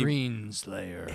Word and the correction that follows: Screen Slaver: Well, okay, I've been Screen Slaver. – Screen Slaver. – I didn't say it right.